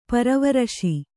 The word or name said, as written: ಪರಿವರಿಸು